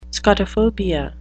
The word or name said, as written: scotophobia